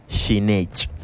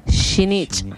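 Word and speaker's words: xinich: Y en lengua yocotán se pronuncia shinich. – Shinich. – Se preocupen, no se preocupen, allá en casita o donde nos escuchan, vamos a volver a repetirlo y por favor pronúncielo con nosotros. Shinich. – Shinich. – Una vez más, shinich.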